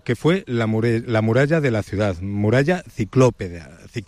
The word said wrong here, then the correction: ciclópea: Creo que lo he dicho bien, no lo sé. – Sí, es la muralla ciclópea ya del siglo IV,